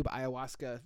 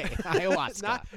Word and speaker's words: ayahuasca: A-Y-A-H-U-A-S-C-A. – Right. See, because I would have gone out there and said ayahuasca. Iowa, I-O-W-A, and then Polish ending.